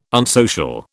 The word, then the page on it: unsocial: British English